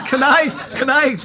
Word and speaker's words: Kanai: Yeah, Kanai rhymes with I'll die.